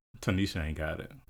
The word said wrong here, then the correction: Tinashe: You know, we'll stop calling her Tanisha. – Her name, that's how you pronounce it, right? – No, I think it's Tinashe.